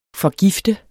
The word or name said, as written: forgift